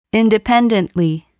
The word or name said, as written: independently